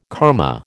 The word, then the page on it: karma: American English